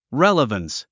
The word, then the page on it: relevance: réləvəns; レラバンス